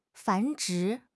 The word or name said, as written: 繁殖